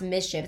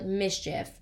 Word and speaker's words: MSCHF: Not only are they mad about that, he also made these shoes with a brand called, I think it's pronounced Mischief. It's like M-S-C-H-F. So, like, Mischief, whatever.